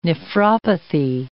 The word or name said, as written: nephropathy